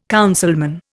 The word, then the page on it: councilman: Indian